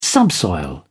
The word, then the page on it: subsoil: British English